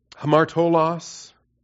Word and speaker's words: hamartolos: Hamartolos, the Greek hamartolos, H-A-M-A-R-T-O, long O, L-O-S, short O.